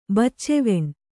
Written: ಬಚ್ಚೆವೆಣ್

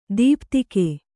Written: ದೀಪ್ತಿಕೆ

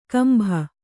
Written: ಕುಂಬಿ

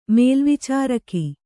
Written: ಮೇಲ್ವಿಚಾರಕಿ

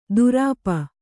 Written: ದುರಾಪ

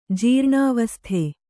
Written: ಜೀರ್ಣಾವಸ್ಥೆ

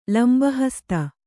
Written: ಲಂಬಹಸ್ತ

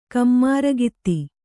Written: ಕಮ್ಮಾರಗಿತ್ತಿ

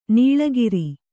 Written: ನಾಲಿಕೇರ

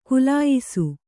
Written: ಖುಲಾಯಿಸು

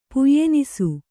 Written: ಪುಯ್ಯೆನಿಸು

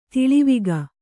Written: ತಲೆವಾಗು

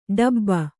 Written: ಡೆಬ್ಬೆ

